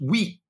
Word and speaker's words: skit: In the Swedish language, skit is the word for shit, but it is pronounced wheat.